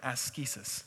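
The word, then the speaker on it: askesis (ἄσκησις): But I'm going to use it with the Greek pronunciation, with the hard K, the askesis.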